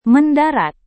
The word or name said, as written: mendarat